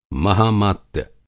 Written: महामात्य

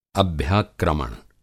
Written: अभ्याक्रमण

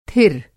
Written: थिर

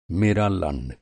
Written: लंड

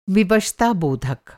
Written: विवशताबोधक

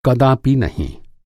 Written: कदापि

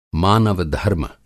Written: मानव-धर्म

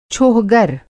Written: छोहगर

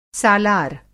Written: सालार

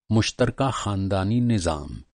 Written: निज़ाम

